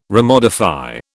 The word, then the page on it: remodify: British English